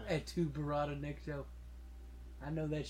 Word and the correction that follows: Klaatu: You're saying it wrong. – Am I? – It's Klaatu.